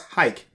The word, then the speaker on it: Huyck: Oh, his last name is pronounced Hike, H-I-K-E, even though it's spelled H-U-Y-C-K.